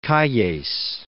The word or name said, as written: Calles